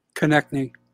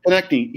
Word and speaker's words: Konecny: Uh, you know, I, I, Travis uh, was it, how do you pronounce the Kokanee? Konecny. Konecny.